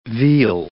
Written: veal

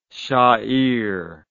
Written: shair

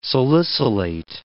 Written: salicylate

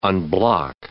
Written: unblock

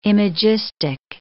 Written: imagistic

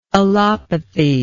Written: allopathically

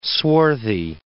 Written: swarthy

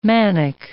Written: manic

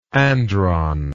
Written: andron

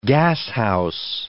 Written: gashouse

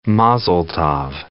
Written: mazel tov

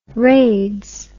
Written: raids

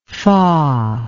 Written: foh

